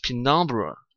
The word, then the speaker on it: penumbra: 哎，它是 penumbra。